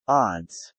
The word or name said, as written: odds